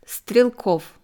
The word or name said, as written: Strelkow